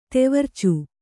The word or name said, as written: ತೊವರ್ಚು